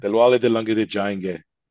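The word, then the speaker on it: Dilwale Dulhania Le Jayenge: The Diwale Dulhania Le Jayanga. Did I say that right? – Diwale Dulhania Le Jayanga. You did? Yeah, you did.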